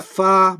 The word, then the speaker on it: Hua: Enter Fa Mulan, and I'll remind you that Fa is her family name, mm-hmm. – and Mulan is a personal one. And it's spelt H-U-A in Romanized alphabets, and it means literally flower or magnolia, if you want to get specific.